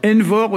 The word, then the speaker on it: envor: Envor aussi, la mémoire, hein. Donc c'est comme énorme mais avec un V, E-N-V-O-R.